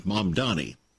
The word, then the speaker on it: Mamdani: Hard to remember how to pronounce the name, Mamdani. It's M-A-M and then D-A-N-I, Mamdani.